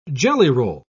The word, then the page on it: jellyroll: British English